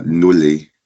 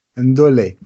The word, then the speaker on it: Ndolé: Und da haben wir Ndolé. Spricht man das so richtig aus? – Ja, Nulli. – Ndolé.